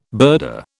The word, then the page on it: birder: British English